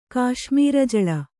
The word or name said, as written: ಕಾಶ್ಮೀರಜಳ